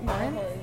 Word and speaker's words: Horan: Horan, yeah.